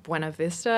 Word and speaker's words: Buena Vista: I know that they released it through their niche distributor, which was Buena Vista, I think is how you say it, totally.